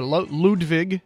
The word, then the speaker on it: Ludwig: Also some Ludwig or Ludwig, if we're going with the German pronunciation, drums, drum set.